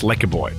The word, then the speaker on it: Lekker Boy: Their new album, which I stupidly, as you'll hear in a second, mispronounced. I'm sorry. It's Lekker Boy. Not what I ended up saying.